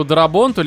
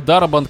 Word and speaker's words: Дарабонт: Не, короче, мне кажется, что Здесь, конечно, нужно было бы отдать Фрэнку Дарабонту, либо Дарабант, как там, Дарабонт, скорее всего, ударение.